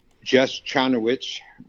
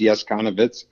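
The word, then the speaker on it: Jess Chonowitsch: I saw the name Jess Chanowicz, which is actually pronounced Yes Konowicz, and I called him, and we scheduled an appointment for lunch.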